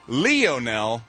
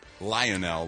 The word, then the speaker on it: Lionel: Pronounces his name Lionel, like Leo, not Lionel, like lion.